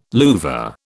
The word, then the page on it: louvre: British English